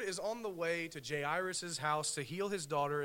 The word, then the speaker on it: Jairus: a man named Jairus,